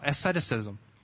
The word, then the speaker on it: asceticism: I know how to say it then. I was worried I don't know how to say it at all. Asceticism. Oh, good. Did I say it right? Good.